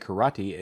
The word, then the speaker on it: karate: Proper way to say karate is ka-ra-te, not ka-ra-te, ka-ra-tai.